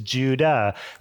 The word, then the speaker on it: Judah: The Hebrew or the Greek way to actually pronounce Jude is Judah.